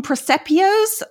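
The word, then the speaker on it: presepio: Presepios? These little nativity scenes? Is that how you say it? – Yeah. Yep.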